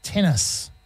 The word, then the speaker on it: TENACE: Tennis. Spelled T-E-N-A-C-E. How would you pronounce that? – I can't. Tennis. – Tennis, there we tenace. – Go. Just tennis, not tennis.